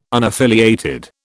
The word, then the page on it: unaffiliated: British English